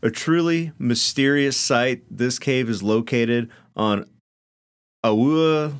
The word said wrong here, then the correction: Oahu: leeward's coast, north of Wanae. – Oahu. – What? Oahu? – It's Oahu.